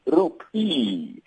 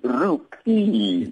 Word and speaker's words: rupee: Just to correct you, it's rupee, the Indian rupee. That's how you pronounce it.